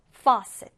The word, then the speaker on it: faucet: 여기 발음 기호 보시면 이렇게 왼쪽으로 뚫린 O 표시가 있잖아요. 이거는 이제 O와 어의 중간 발음이라고 보면 돼요. coffee 할 때 커처럼 faucet, faucet 이렇게 발음을 하는데요.